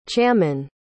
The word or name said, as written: chaman